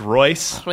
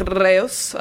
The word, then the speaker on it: Reus: Reus? – Or if it's German, it's Royce. – Reus. – Reus. Let's go with Reus. R-E-U-S.